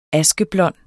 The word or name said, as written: askeblond